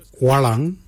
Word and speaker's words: walyan: The first colour is walang. Walang is blue. Walang is spelled W-A-L-Y-A-N.